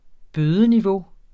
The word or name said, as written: bødeniveau